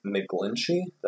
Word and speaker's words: McGlinchey: Yep, they signed, they drafted Mike McGlinchey.